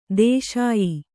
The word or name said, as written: ದೇಶೀಯ